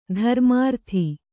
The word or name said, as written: ಧರ್ಮಾರ್ಥಿ